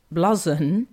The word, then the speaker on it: blouson: Well, Gareth says my grandad would have called it a blouson, pronounced blazon.